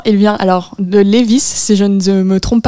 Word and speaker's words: Lévis: Faut juste pas prononcer le, S. – de Lévis.